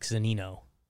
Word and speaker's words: Zunino: Let's snag a couple cheapies today off Zaniners. – Yeah. – Am I saying that right? – Are you close? All around it? What are you watching for? – Who's this catcher? Zaniner? No, Mike Zunino.